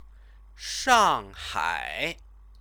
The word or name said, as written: shanghai